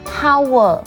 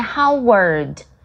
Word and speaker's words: Howard: 或者是霍华德， OK, 他的英文很多人把它念成 Howard, OK, 其实他是念 Howard, 然后后面的 D 大家好像都没有发到。